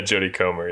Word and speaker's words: Jodie Comer: And um I've not seen is it Jodie Comer? Is that how you say her name? – Yeah, Jodie Comer, yeah.